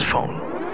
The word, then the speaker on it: Phaune: It's spelled P-H-A-U-N-E, but it's pronounced phone.